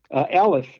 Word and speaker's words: aleph: Now, both the ion and the aleph, they are silent letters. They both are silent letters. They are only given the vowel of which you give to it. Mm-hmm. And since you give the ion kind of an A sound and the aleph A sound, they are pronounced alike.